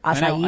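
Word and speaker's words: açaí: That's how you pronounce that word. Acai.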